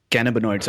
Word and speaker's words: cannabinoids: So three key things which I noted from what you said was there are over 100 different types of cannabinoids.